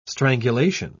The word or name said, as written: strangulation